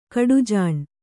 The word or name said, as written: ಕಡುಜಾಣೆ